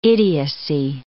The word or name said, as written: idiocy